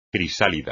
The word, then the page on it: crisálida: espagnol